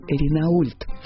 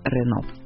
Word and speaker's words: Renault: До сих пор на находятся сограждане, упоминающие в беседе некие Пеугиот и Ренаульт. В то время как правильное произношение намного легче и благозвучнее. Помечаем. Основатель автомобильного производства Peugeot звали Арман Peugeot, а компанию Renault создали братья Луи, Марсель и Фернан Renault. Сузуки.